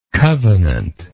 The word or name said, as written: covenantally